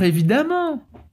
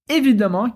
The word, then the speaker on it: évidemment: E aqui, rapidinho, quero chamar sua atenção para essa palavra évidemment, que tem um detalhe de pronúncia muito interessante. Toda vez que a gente tem uma palavra terminada em "-ement", no francês, ela vai ser lida com som de A, por isso, amant.